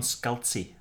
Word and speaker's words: Scalzi: Is that how you pronounce it? – I have no idea. – Okay. – Do you know how to pronounce it? – I don't, let me see it. Yes, John Scalzi.